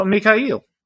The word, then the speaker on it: Mikhail: And it's very hard for my tongue to do, but you put the emphasis on the last syllable, and that syllable is definitely eel, like the fish. So, Mikhail.